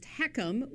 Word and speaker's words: HECM: That's pronounced HECM, which is kind of crazy.